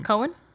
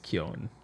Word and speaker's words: Keoghan: Cohen? No. – No, I think it's Keoghan. – Keoghan.